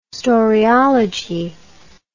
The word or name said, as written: storiology